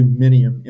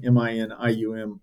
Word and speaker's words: Minium: If you just do Minium, Minium, I'm going to pop up.